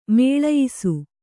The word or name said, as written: ಮೇಳಯಿಸು